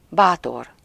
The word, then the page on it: brave: /ˈbreɪv/